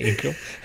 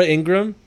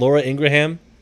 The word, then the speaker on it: Ingraham: It's really Ingram. So wait, no, there's somebody that's famous with the last name that's spelled Ingraham, right? – It's still Ingram. It's still Ingram. – Like Laura. It's Laura Ingram, not Laura Ingraham.